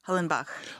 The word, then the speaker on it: Hollenbach: Julie Hollenbach? – Hollenbach. – Hollenbach. – Yeah, like the composer, you know.